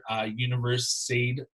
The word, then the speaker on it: Universiade: Universiade Tournament, if I pronounced that correctly.